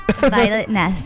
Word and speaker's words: Violetness: Violetness.